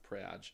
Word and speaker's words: Prague: No, it's pronounced Prague.